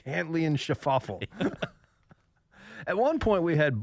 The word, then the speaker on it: Schauffele: And then Cantlay and Schauffele, the two most mispronounced names in all of golf now. Cantlay. – L-A-Y. – Schauffele.